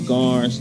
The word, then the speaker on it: Garnes: Edward is spelled just like Edward, and my last name is Garnes, G-A-R-N-E-S.